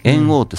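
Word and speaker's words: 猿桜: はい、じゃあ、そのやんちゃな頃にね、猿王って桜、うん、あ猿の桜と書いて、猿王っていうね、うん、しこ名をもらうんですけども、その、はい、主人公が。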